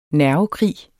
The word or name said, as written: nervekrig